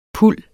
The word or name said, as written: puld